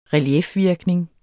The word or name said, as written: reliefvirkning